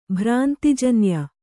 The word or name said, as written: ಭ್ರಾಂತಿಜನ್ಯ